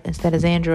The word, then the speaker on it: Zandra: And they think I'm Sandra instead of Zandra. Or they think I'm Zandra instead of Zandra. So, you know, the proper spelling is actually Z-A-N-D-R-A and the Zandra.